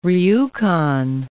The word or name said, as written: Rjukan